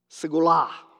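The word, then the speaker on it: segula (סגולה): It's pronounced segula. Segula. So this is your Hebrew lesson for the day.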